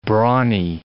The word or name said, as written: brawny